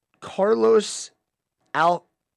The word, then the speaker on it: Carlos Alcaraz: His name is Carlos Alcaraz.